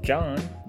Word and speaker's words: Jan: That's J-A-N, Jan, okay? – But it's okay. Pronounced John. – But it's pronounced John, but there's people out there that say Jan, and it's hilarious.